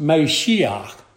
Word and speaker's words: Mashiach: Now the Hebrew word anointed is pronounced Mashiach. And it's the same word used for the English word Messiah.